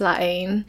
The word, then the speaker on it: La Haine: On this episode, we're going to be talking about La Haine, which I used to call La Haine before I learned about the French pronunciation. – Yeah, I didn't know up until like a few days ago it's pronounced La Haine after watching like a commentary made by the director on Criterion, you know.